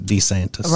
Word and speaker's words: DeSantis: DeSantis.